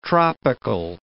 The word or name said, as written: tropical